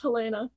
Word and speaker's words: Helena: My name is pronounced Helena.